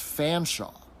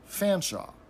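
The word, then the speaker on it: Featherstonhaugh: but if you add on H A U G H to the end of it, it completely changes the pronunciation of the name and becomes Fanshawe. So you have like this 17 letter name that gets reduced to Fanshawe.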